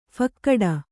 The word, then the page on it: ಫಾಕಡ: phākaḍa